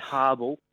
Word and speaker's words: Habel: It is pronounced, it's Habel. Habel.